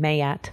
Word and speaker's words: Maat: So for my deck, I base it around an Egyptian principle called the 42 ideals of Maat. Some actually pronounce her name Mayat,